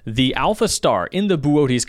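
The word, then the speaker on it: Boötes: So I looked it up, and it is the constellation B-O-O-T-E-S. Like Booties. – Booties. Booties. – Booties. – Boots. It's actually pronounced Booties.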